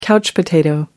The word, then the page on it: couch potato: American English